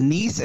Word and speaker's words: Nice: It's Nice. It's spelled like nice.